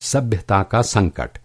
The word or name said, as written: सभ्यता